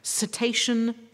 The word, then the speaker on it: Cetacean: Now, forgive me if I pronounce this incorrectly. Cetacean Strandings Programme Manager. – That's right.